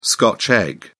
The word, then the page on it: scotch egg: British English